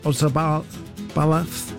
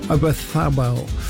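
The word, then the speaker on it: Oyarzabal: Lamine Yamal, Olmo, Bayena and Ozabalaf. Is up front. I didn't say that right, did I? I know. Obathabel.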